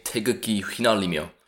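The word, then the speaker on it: 태극기 휘날리며: You say Tae Guk Gi Hwinalimyo, which means waving the flag, yeah.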